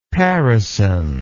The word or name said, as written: parison